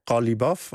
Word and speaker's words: Qalibaf: Dat is Qalibaf, spreek je dat in Persisch uit.